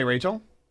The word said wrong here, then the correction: Rachelle: It's Rochelle. You do that every time, but it's okay. – Well, sorry. – Way to go. – I mean, it's spelled Rachel. Talk to Justin. I can't. – Really? Because I spelled it for him, too. – How do you spell it? – R-A-C-H-E-L-L-E. – Oh, well, see, that looks like Rachel to me. – No, that looks like Rochelle.